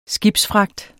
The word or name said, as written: skibsfragt